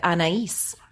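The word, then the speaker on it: Anaïs: You pronounce it Anais.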